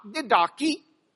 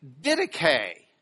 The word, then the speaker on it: Didache: It's not Didache. It's not D-dash. It's Didache. Didache.